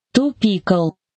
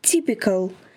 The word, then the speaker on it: typical: Чаще всего, читая его впервые, его произносят как «to pickle». – Это неправильно. Запомните. Типичный – «typical». Давайте повторим еще раз. «Typical».